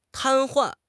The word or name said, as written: 瘫痪